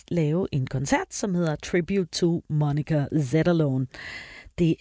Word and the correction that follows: Zetterlund: Og jeg tror lige, jeg kom til at udtale Zetterlund lidt forkert. Det er Zetterlund, Monica Zetterlund, Tribute to Monica Zetterlund.